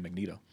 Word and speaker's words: Magneto: I prefer the pronunciation Magneto.